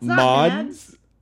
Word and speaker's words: Mads: Yeah, it's Mads.